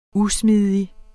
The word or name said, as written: usmidig